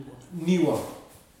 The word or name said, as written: aniwa